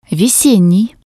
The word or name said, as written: vesennii